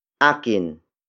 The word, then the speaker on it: akin: Akin. Akin. Akin is spelled as A-K-I-N.